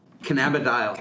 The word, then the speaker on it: cannabidiol: It's, first of all, CBD is cannabidinol. Is that correct? – I guess. – Or how do you say it? – This is my pronunciation is, now I'm going to say it wrong. cannabidiol. – Cannabidiol. Okay. – Yes. This is the Canadian pronunciation that you hear at the medical conferences.